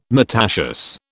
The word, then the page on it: myrtaceous: British English